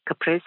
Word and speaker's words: caprese: Yes, I was listening to the Zorba pastor on your health show today, and he said it's caprese salad. It's not. It's caprese. Caprese is a Italian term for a tomato and mozzarella dressing. So just wanted to correct his pronunciation.